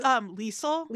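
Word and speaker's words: Lisle: She goes to, how do you say her name? Lizzle? – I think it's Liesl. – Liesl, sure. – Because when I was younger, I thought her name was Lisa. – Okay, Liesl.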